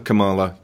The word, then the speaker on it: Kamala: We'll call her Kamala to rhyme with koala.